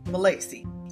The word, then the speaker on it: Milacci: So my guest today is CJ Milacci.